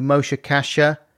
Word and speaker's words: Moshe Kasher: But I was watching you talking to Moshe Kasher. And am I saying his name right? – Yeah.